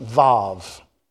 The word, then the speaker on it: Vav: I know it's spelt Waw, but it's actually pronounced Vav.